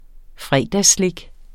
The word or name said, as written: fredagsslik